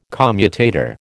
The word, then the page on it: commutator: American English